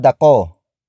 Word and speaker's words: ako: Lumakad ako. Lumakad ako. Lumakad ako.